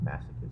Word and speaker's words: masochism: Oh, is that pronounced masochism? – Yeah. – I never knew that's how it was pronounced.